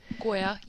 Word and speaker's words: Gioia: So he and another man, Tony Gioia, I don't know how it's pronounced, G-I-O-I-A, Goya? – Goya, yeah.